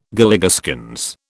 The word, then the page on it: Galligaskins: British English